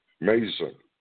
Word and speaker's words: meizon: Those who believe, and then greater works, the word there is M-E-I-Z-O-N. It's pronounced mason.